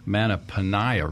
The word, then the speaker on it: Mattaponi: Manitoba Manitoba River. No, I mispronounced that. Mattaponi River.